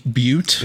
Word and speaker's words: Butte: Let's name this town Butt, but with an e at the end. All right. – It's pronounced Butte.